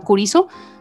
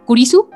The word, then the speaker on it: Kurisu: Okabe siempre le está poniendo apodos porque, por ejemplo, ella se llama Kuriso, Kurisu,